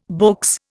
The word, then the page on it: beaux: Indian